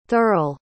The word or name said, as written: thurle